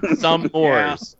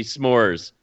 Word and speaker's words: s'mores: some Moors. Yeah. It's S apostrophe Moors. It's hyphenated. It's supposed to be s'mores, not some Moors.